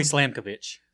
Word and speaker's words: Slamkovic: First, you have, I want to try to pronounce these correctly, Biff Slamkovic, or Slamvok. – It's got to be Slamkovic. – Slamkovic. – Like a sandwich and a slam.